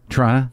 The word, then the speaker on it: Toronto: Toronto.